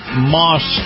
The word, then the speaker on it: Maus: And this portion of the program brought to you by Moss Nissan. Moss is spelled M-A-U-S, Moss Nissan.